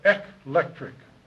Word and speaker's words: eclectic: I'm not saying electric, but eclectic.